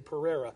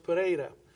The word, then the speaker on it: Pereira: You would probably say Pereira. In Portuguese, it's pronounced Pereira.